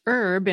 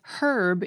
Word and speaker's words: herb: For example, the word for a certain kind of plant is pronounced herb in American English and herb in British English.